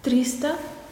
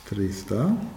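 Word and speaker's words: триста: Trista. – Trista.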